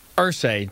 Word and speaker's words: Irsay: And speaking of falling apart at the seams, I see the owner is it Ursay? Irsay? How do you say this man's name? – Ursay. Jim Ursay.